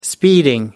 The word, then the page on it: speeding: American English